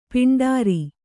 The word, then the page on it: ಪಾಂಡುರ: Kannada